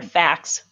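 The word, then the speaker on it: FACS: Or facts. – Facts, yep. Yeah, with the S on the end, right?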